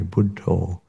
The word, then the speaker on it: Buddho: which in Thai works quite neatly because they pronounce the word Buddha in a slightly different way, Buddho.